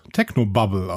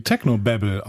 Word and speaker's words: Technobabble: aus Berlin äh, schrieb, von mir nur eine kurze Beschwerde, ihr benutzt oft das englische Wort Technobabble, sprecht es aber wie das nicht existierende englische Wort Technobubble aus. es wird aber quasi Technobabble ausgesprochen, ist ja ein englisches Wort wenn wir nämlich Techno-Bubble sagen.